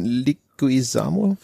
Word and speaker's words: Leguizamo: Der in Luigi spielt John Leguizamo. Wie spricht man dessen Nachname aus? – Weißt ja, das war schon richtig.